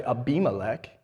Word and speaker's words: Abimelech: Abimelech is just 2 Hebrew words put together. It's Av, father, and Melech, king. And when it's put together and pronounced this way, it's actually Abimelech.